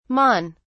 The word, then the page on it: mon: British English